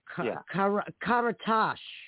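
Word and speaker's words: Karatas: Hey, well, technically it's Karatash, but there's no H at the end, so no one could ever get How the hell was I supposed to know that? – You weren't.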